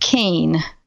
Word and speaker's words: Kean: My website is Surviving Death Kane, and so it's the title of the book plus my last name, which is K-E-A-N.